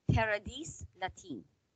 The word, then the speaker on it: Paradis Latin: No, Paradis Latin. – Oh, I thought you don't pronounce the S. – I told you, like all languages, there are exceptions.